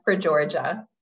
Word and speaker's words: Frageorgia: Joanna Frageorgia.